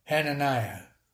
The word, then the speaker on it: Hananiah: Hananiah. – I like the Hananiah.